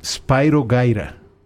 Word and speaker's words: Spyro Gyra: Pero el mero mero, ¿sabes cómo lo pronuncian? – ¿Cómo? – Spyro Gyra. – Ah, órale. – Porque es de procedencia, o sea, no es inglés ni norteamericano.